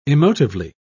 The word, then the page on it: emotively: British English